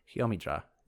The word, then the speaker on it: Jommydra: This body of sacred scripture is called J-O-M-M-Y-D-R-A. Jomidra is what I'm going to pronounce it as.